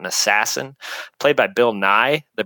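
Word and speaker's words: Nighy: And I looked endlessly on how do you pronounce the guy's name. And I saw Nye over and over. So I'm sticking with it.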